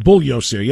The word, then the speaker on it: Bugliosi: I was wondering, are you familiar with Mr. Bugliosi? – Bugliosi, yes. Soft G.